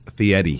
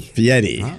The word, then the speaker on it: Fieri: Well, the reason why I know it is because when I used to announce his name on The Tonight Show, it was spelled phonetically, and it was Fieri. – Fieri.